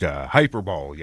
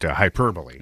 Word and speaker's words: hyperbole: Puss mispronounced hyperbole yesterday. – Yeah, he did. – When yeah. – he called it hyperbole. – Yeah. Yeah. It is hyperbole.